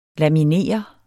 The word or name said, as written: laminere